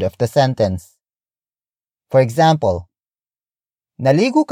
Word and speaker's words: naligo: Word for word it's, naligo, ka, ba. Naligo, ka, ba. Naligo, ka, ba. The first word is, naligo. Naligo. Naligo. Naligo is spelled as N-A-L-I-G-O.